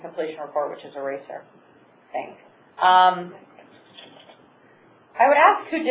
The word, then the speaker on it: RACR: Construction activities were complete about 10 years ago, but there is no PCOR or RACER. I'm pronouncing the acronym, but it's RACR.